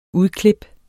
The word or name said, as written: udklip